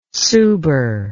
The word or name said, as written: suber